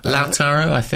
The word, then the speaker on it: Lautaro: Lautaro Martinez.